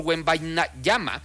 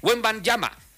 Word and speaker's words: Wembanyama: Así que los Spurs todavía tienen vida gracias al enorme impacto de Víctor Wembanyama, quien ha convertido Wembanyama. Ya lo dije bien.